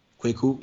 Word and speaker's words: Kweku: Wednesday, mm-hmm. Kwaku? – Kweku, yep. – Kweku.